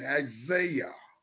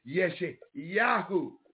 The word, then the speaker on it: Yeshayahu: A lot of you call it his name Isaiah. That is not his name. That is not correct pronunciation of his name. His name is Yeshayahu. Get it right.